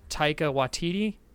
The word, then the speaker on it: Taika Waititi: Uh, Taika Waititi. Did I get it right? – It sounds like what it looks like.